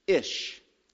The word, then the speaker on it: ish: Next, this is the top of page 12, is ish. Ish is how you pronounce it.